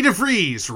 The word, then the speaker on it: DeVries: And we were very lucky to get to see the wonderful, the incredible Vicky DeVries Rhymes with Cheese.